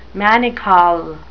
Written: manacle

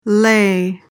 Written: lei

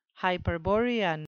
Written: hyperborean

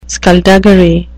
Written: skulduggery

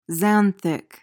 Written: xanthic

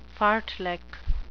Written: fartlek

